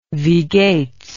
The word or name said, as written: wie geht's